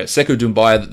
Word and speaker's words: Sekou Doumbouya: Seemed to disappoint a little bit, but the hype with Sekou Doumbouya, did I pronounce that correctly? I don't think I've ever heard his name pronounced. – I think that's right. – Okay, Sekou Doumbouya, the hype now is really, really ramping up for him.